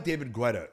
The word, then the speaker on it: David Guetta: I like David Guetta.